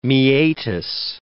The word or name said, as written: meatus